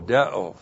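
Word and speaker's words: deo (δέω): D-E long O, deo.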